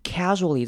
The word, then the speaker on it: casually: Casually 这个字要注意的是，它开头的 a 呢要念成短母音 a 的声音，跟前面 action 一样。 Casually， casually。好，后面的 sua 这个念法也要特别注意，念 rua， rua，有点像是念 g 跟 j 的合在一起的 rua， rua。